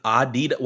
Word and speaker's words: Adidas: With Adidas? Why'd you say it like that? – That's the correct way to say it.